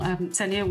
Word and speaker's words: Ksenia: Senia, would you like to introduce yourself, please? – Yes, thank you so much for having me here. So my name is Senia Castanenka, that's Senia K is silent, and I'm an assistant professor here at Massachusetts General Hospital and Harvard Medical School.